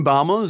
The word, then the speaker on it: Baumol: But what I found really compelling about the explanation in your book for this discrepancy, you point to something called the Baumol effect. I think I'm pronouncing it right, the Baumol right. effect. What is that and where does it come from? – Right. So William Baumol is an economist.